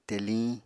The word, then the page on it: teliñ: apatani